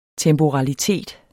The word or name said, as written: temporalitet